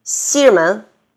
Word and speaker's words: 西直门: 张罗，张罗，还有好多呢，是就是中间那个字儿啊，就不发音的。稻香村，稻香村。老师好，老师好。西直门，西直门。